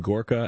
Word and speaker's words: Gorka: It's Dr. Sebastian Gorka and his wife, Catherine yes.